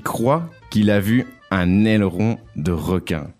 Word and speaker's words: aileron: Aileron. – Yeah, let's do some pronunciation because I've always found that word hard to say. – Aileron. – Aileron.